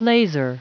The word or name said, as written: laser